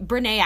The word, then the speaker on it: Brené: So basically, Brené actually has an accent on the last E, but we obviously you can't do that on your email.